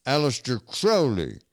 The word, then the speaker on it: Aleister Crowley: Aleister Crowley.